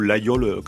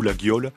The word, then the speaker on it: Laguiole: Par contre, on dit plutôt Laguiole quand on est Aveyronnais, ou Laguiole si on souhaite le prononcer un petit peu à la française.